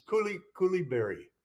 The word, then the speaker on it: Coulibaly: the player in the draft that everybody's comparing to Anunoby, it's that, gotta get his name right, Bilal Coulibaly. – Yes, yes. Not bad. Yeah, it's pretty close. – He's the wing. – I can't really pronounce it correctly. – It's Coulibaly.